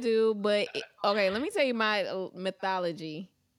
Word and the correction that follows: methodology: Mythology? Did I say it correctly? I feel like there's methodology. – There it goes. I'm missing a syllable.